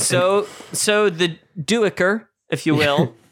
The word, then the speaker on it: Duiker: Starting with Diker, one of my favorite characters. – Duiker. Love him. – But that's how they say it in the audiobook.